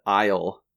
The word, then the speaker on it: isle: And probably the favorite one out of this list is island and isle. That's I-S-L-E, isle. Nothing to do with each other, despite referring to the same thing, and both of them having a silent S.